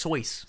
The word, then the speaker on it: Seuss: Dr. Seuss. – Dr. Seuss. – Dr. Seuss. – Yeah. – Which is actually pronounced Soyce.